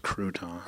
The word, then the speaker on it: Croton: It's pronounced Croton. Go ahead. Croton.